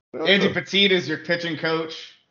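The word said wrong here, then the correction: Pettitte: Did you say Andy Petit? – Is that not how you pronounce it? – It's Pettit.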